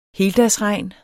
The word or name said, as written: heldagsregn